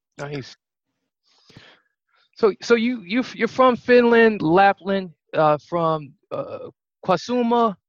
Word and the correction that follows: Kuusamo: I pronounced that right? – Yeah, almost there. It was Kuusamo. – Kuusamo. Yeah, almost had it.